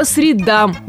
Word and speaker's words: средам: Курсы проходят по средам или же по средам? Итак, важно запомнить, что если речь идет о дне недели, то вариант единственно верный – по средам. Да-да, по средам и никак иначе.